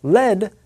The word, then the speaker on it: lead: The noun led sounds like the noun head.